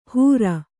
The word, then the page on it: ಹುರ: hura